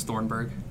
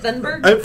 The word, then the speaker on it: Thunberg: I thought it was Thornburg. – It's not Thornburg. – Yeah, so I've been saying it wrong. That's what it is. – What is it, Thunberg? – First, I was like, maybe Thunderbird. – Maybe I'm saying it wrong, but it's definitely not Thornburg.